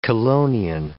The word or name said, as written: chelonian